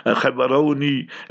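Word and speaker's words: Gaborone: And I must thank all the brothers, sisters, children there in Botswana, in Gabs, Gaborones, Khabaroni, as they pronounce it there, and then Lobatsi, and then mashallah, there, mashallah, in Kanye.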